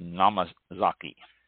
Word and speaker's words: Namazake: Refrigeration is especially important for unpasteurized sakis called Namazake, N-A-M-A-Z-A-K-E, and also for the very fruity Ginjo styles of sake, or Ginjo, G-I-N-J-O with long O, Ginjo.